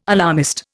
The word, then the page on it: alarmist: Indian